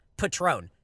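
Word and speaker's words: Patrón: Finally, it's pronounced patron.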